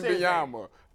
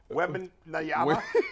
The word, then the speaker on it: Wembanyama: To say, how do you Wembanyama. – Wembanyama.